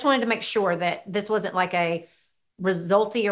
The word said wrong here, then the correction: Rexulti: What did you say it was? I don't want to say it wrong. – Rick Salty. – Rexulti. Yeah, I knew it. I know the commercials, so I knew I was saying it wrong.